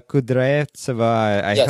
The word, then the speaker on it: Kudryavtseva: And interesting also, because I talked to Alla Kudryavtseva. Hope I pronounced that correctly. – That was perfect, actually.